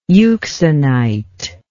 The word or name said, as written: euxenite